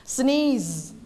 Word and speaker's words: sneeze: sneeze คำไหนคะคำว่าเมื่อกี้ควานออกเสียงว่าไงนะ sneeze เพราะตัวนี้ตัวอะไรตัว z มันต้องทำไมในคอคะสั้นในคอไม่อ่าน sneeze นะคะไม่ออกเสียงต้องออกเสียง z sneeze